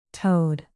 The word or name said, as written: toed